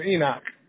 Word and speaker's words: Enoch: My name is Enoch.